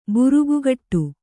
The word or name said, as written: ಬುರುಗುಗಟ್ಟು